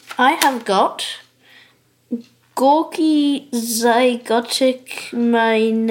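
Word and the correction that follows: Gorky's Zygotic Mynci: This is Gorky's Zygotic Monkey.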